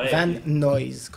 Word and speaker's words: Van Nuys: Ah, Van Nuys, comme on dit. Van Nuys. Noise.